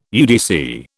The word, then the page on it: UDC: British English